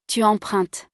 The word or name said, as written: tu empruntes